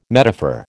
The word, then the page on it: metaphor: American English